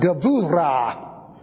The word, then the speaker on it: gevura: B-U-R-A-H, pronounced Gavura. The B is pronounced like a V in the middle of a word.